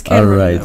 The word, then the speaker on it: Can Rotterdam: Am I pronouncing it right? – Yeah, it's Can Rotterdam.